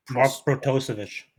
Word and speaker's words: Protosevich: New park came with Mark Protosevich. Yeah. – Yeah, that's how you say it.